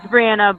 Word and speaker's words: Brianna: It's Brianna, but living in different places of the U.S., I get both, and I respond to both, so don't worry.